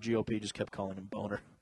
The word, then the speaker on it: Boehner: It's Boehner.